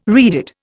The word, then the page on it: reeded: Indian